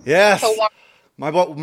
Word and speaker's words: Tawanna: Is it Tawanna? – Yes. – Tawanna.